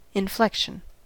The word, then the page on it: inflection: American English